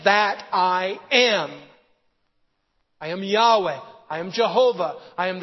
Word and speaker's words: Yahweh: The old way to translate this name was Jehovah. The more modern way is to pronounce it Yahweh.